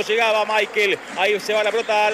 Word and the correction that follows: Mikel: ¿Cómo le decimos? ¿Michael o Mikel? Nicolás Álvarez, usted que es especialista en nombre. Se escribe Mikel. ¿Cómo le digo? – Decirle Mikel. – Entonces le digo a Mikel, usted me enseña y yo aprendo.